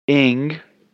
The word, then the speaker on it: Ng: Ing, spelled it I-N-G, or Aang, spelled it E-N-G, work.